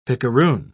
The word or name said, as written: picaroon